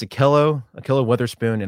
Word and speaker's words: Akello Witherspoon: Man, I, as of this exact moment, I think it's Akello Witherspoon, and I feel like I'm butchering his name. And you got it right. That's right. That's right. Yep.